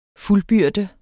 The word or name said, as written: fuldbyrde